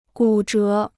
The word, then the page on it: 骨折: gǔ zhé